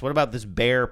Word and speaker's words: Behr: Is that how you say it? B-E-H-R? I think it's bear. – Bear, yeah. I think it's bear. I think, in fact, their logo is a bear, like an animal, even though it's spelled differently.